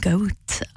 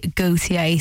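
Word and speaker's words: Gotye: I have been calling him Goat. I thought that's how you pronounced it. But no, it is actually Gautier.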